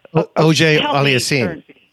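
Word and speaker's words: Auger-Aliassime: And then I'm going to butcher his name, Felix AA. – OJ Aliassime. – Thank you. Yes, yes.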